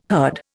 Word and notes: herd: Indian

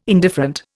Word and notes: indifferent: Indian

